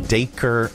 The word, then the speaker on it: Dacre: Dacre Montgomery. – Dacre Montgomery.